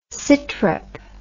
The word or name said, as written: sitrep